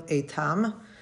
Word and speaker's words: Eitam: Eitam. His last name is spelled E-I-T-A-M.